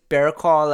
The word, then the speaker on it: Barraclough: Is it really just Bearclaw, like B E A R C L A W? That's how it's pronounced. Spelling may be different, though.